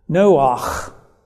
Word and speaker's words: Noach: Noach is the way it's pronounced in Hebrew.